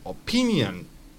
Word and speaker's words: opinion: Por ejemplo, opinión va a ser opinion. Opinion. Fíjate cómo me llevo la entonación hacia adelante.